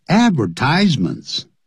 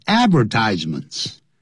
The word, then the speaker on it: advertisement: 我们文字的广告呢叫做 advertisements，通常老外是念成 advertisements， A-D-V-E-R-T-I-S-E-M-E-N-T，复数加个 S。通常因为这个 advertisements 太多太长了，所以一般就讲 ads， A-D-S。咱们就是这样说， I never read ads，我从来不看广告的。如果是英国人还会念成 advertisement， advertisement，那是英国人念法。那美国人是念的 advertisement。